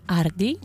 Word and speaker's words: Hardy: Tam jest nieme H, więc François Hardy. Nazywa się, pisze się Hardy, a mówi się Hardy.